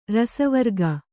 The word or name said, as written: ರಸವರ್ಗ